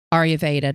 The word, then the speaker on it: Ayurveda: And so this holistic lifestyle known as Ayurveda, did I pronounce that right? – Yes.